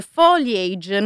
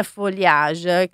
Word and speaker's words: foliage: che in realtà la pronuncia corretta di foliage è all'inglese foliage, non foliage, quindi in francese.